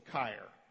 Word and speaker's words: Keir: So if you have German background, you know that an E-I, contrary to English, in German, E-I makes a long I sound, so Keir.